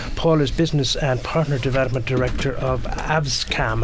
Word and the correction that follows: ACASM: Have I pronounced that correctly, Paul? Uh, ACASM. ACASM. ACASM. Uh, yeah, it's an acronym me.